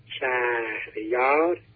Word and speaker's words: شهریار: اما چرا ما شهریار تلفظ میکنیم؟ شهریار تلفظ نمی کنید. – بله. – که دو تا هجای بلند هم یک کلمه دارای دو تا هجای بلند هست. شهریار، شهر یک هجای بلنده، یارم یک هجای بلنده.